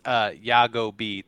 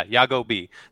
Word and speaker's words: Yagobi: I should have did this a while ago, but it's Yagobi, three syllables. So Yago and B. Oh, that's two, actually. But yeah, Yagobi. That's how you pronounce it.